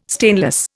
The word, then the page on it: stainless: Indian